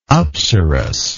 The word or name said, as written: apsaras